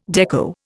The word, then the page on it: dekko: Indian